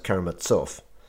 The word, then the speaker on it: Karamazov: I mean, I learned from listening to the audiobook of Brothers Karamazov that it's pronounced Karamazov.